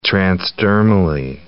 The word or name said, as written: transdermally